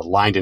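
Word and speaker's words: Lynde: I think I'm saying that right. It is Lyndon. – No, you are. I was going to congratulate you for that. That's yes, it's the Lyndon and Harry Bradley Fund.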